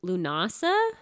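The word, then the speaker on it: Lughnasadh: There's one that we're going to talk about in a minute that's pronounced Lunasa. – Lunasa. – But it looks like Lugnasa. – Yeah, there's a D in there. – There's a lot of, there's like a G and an H you wouldn't expect.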